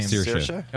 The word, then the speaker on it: Saoirse: Saoirse.